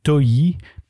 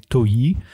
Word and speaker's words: Ntoyi: s'appelle Toyi, mais ça s'écrit N-T-O-Y-I. Mais lui, il dit Toyi.